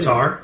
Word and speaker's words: Qatar: Yeah, like, well, and like, Qatar, is Qatar, is that how you pronounce it? – Qatar. – Qatar. – Yeah.